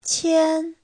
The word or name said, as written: qian1